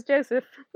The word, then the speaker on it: Joseph: It's I've not given it a more English pronunciation, it says Joseph.